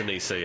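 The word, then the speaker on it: Anisa: That's A-N-I-S-A. I think it's Anissa. – It's Anissa, yeah.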